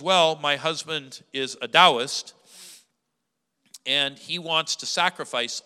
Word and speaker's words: Taoist: Now, if you've ever seen T A O, you might say Taoist, because that's the way we would do that in American English. But the right way to say it in Chinese is Taoist.